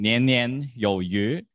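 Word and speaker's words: nian nian you yu (年年有余): Because fish is pronounced yu, and there's a saying, nian nian you yu. Is that correct in Mandarin? – Yeah, that's right.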